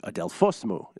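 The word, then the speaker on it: Adelphosmou (Αδελφός μου): Adelphosmou, is how you pronounce that, apparently in Greek.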